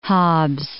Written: Hobbs

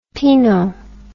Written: pineau

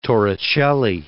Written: Torricelli

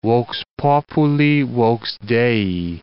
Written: vox populi vox Dei

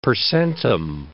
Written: per centum

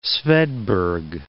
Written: svedberg

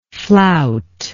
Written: floutingly

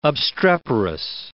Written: obstreperous

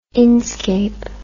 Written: inscape

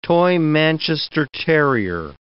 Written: toy Manchester terrier

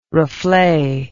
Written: reflet

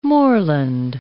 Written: moorland

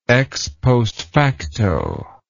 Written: ex post facto

